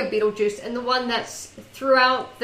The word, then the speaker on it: Betelgeuse: It's Betelgeuse, like a beetle and a cup of juice.